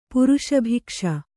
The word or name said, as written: ಪುರುಷಭೈಕ್ಷ